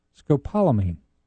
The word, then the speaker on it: scopolamine: But she did say that our buddy Beaver Man needs to get I'm going to butcher this, scopolamine. That's what I said, wasn't it? – Yeah, that's scopolamine.